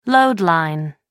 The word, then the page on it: load line: American English